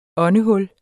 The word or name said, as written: åndehul